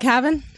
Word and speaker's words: Cavan: Yeah, it's a, a place called Cavan, a pretty, pretty small town in rural Ireland, sort of about two hours northwest of, of Dublin.